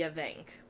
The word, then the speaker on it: Avanc: So, I'm going to say the Avanc, because that is the pronunciation that I heard the most often. And just so you guys are aware, there's different spellings. There's A-V-A-N-C, A-F-A-N-C, and A-D-D-A-N-C.